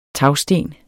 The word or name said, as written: TAGSTENENE